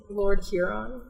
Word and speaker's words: Lord Huron: That, I mean, my favorite Lord Heron song. Meet me in the devil worshipper woods tonight. – Yeah, Lord Huron. – Whatever, I don't know how to say his name. Lord Heron, Lord Huron.